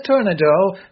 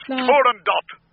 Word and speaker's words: Turandot: Oh, no, the Princess Tornado. La, la, la, la, la, la, la, la. – That's Turandot.